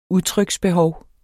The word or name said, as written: udtryksbehov